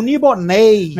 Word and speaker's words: Melniboné: Segundo, que vou dar até uma dica pra galera do inglês, que em inglês ele é o Nibonei, né? Terminando com N-A-Y na pronúncia, apesar de terminar com N-E acento, mas não existe acento no acento em inglês, né?